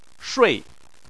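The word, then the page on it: shui4: tone 4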